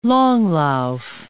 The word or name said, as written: langlauf